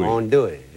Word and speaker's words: andouille: By the way, that's the proper pronunciation of that sausage. Andouille, not andouille. Andouille.